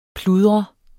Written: pludre

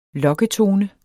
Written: lokketone

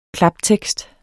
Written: klaptekst

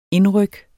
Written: indryk

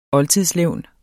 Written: OLDTIDSLEVNET

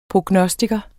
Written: prognostiker

